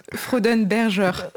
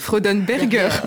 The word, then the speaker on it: Freudenberger: Comment ça se prononce ? – Je suis pas... Freudenberger. – Ouais, je suis... – Freudenberger.